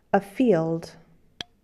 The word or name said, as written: field